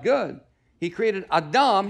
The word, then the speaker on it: Adam: He created small a, Adam, spelled the same as Adam, but pronounced Adam in the Hebrew. He created Adam, which means humanity.